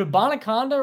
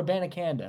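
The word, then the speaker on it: Abanikanda: Is it a Bonaconda or a Banaconda? I've heard both. – A Banaconda. – It is? Okay. – I'm going on Dane Brugler. That's what he said on the pronunciation guide. It said a Banaconda.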